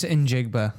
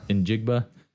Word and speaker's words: Njigba: I think it's just Njigba. – Njigba.